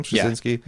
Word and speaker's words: Straczynski: Is that how you say his name, Straczynski? – Yeah. Mm-hmm.